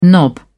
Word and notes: knob: British English

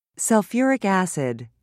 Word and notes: sulfuric acid: British English

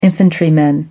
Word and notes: infantryman: American English

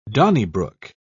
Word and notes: donnybrook: British English

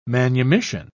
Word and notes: manumission: British English

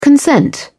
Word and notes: consent: British English

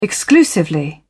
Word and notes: exclusively: British English

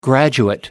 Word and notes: graduate: American English

